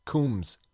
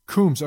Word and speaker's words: Combs: But I got a phonetic pronunciation on it. It's Combs. – Combs.